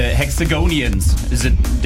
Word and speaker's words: Hexagonians: Don Diablo, your fanbase, the Hexagonians, did I pronounce it right? – Yeah, that's great.